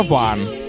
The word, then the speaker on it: one: знаете, как пишется «one» в смысле «единица»? «Оне», e ОНЕ, чтобы было по-вашему. А читается ван.